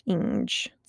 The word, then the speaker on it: Inge: I think the last name is pronounced Inge, spelled I-N-G-E. Inge? I think so.